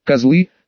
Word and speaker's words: козлы: они же, козлы, с ударением на О.